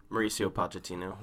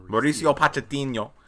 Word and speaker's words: Mauricio Pochettino: Mauricio Pochettino. – Mauricio Pochettino.